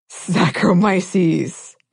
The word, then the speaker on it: Saccharomyces: And it's usually of the genus Saccharomyces or Candida. – Yes. – I hope I got close on that pronunciation. – No, I think that was spot on.